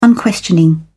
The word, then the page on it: unquestioning: British English